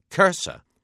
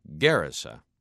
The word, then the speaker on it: Gerasa: The English form of the town's name often gets pronounced Kersa, but as a Hebrew word, translated or transliterated into Greek, it could have come out sounding something very much like Gerasa.